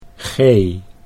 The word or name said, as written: Gee